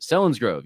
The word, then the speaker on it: Selinsgrove: I think that's how you pronounce it. Selinsgrove, yes, Pennsylvania, which is not far, I don't think, from Lancaster.